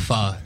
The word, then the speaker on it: pho: I'd probably have to say a little bit of pho. – Yes. Yeah. Let's go. Let's go. – Proper pronunciation there, too. – Yeah. – Pho, not pho. – Absolutely. No, he's right. – It's pho.